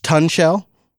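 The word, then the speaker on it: tunshell: It's pronounced Tun Shell, because, you know, it's like tunnel and shell.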